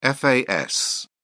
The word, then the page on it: fas: British English